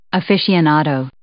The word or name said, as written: aficionado